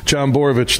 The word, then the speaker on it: Jon Borovich: John Borovich, did I say your name right? – You did, huge.